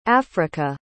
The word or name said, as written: afrika